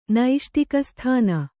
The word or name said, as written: ನೈಷ್ಟಿಕಸ್ಥಾನ